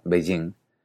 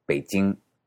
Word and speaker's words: Beijing: En ese sentido, B-E-I-J-I-N-G, que en español tendemos a pronunciar como Beijing, se pronuncia en mandarín como Beijing, es decir, casi como si escribía con P y con che, Beijing.